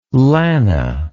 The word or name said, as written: lana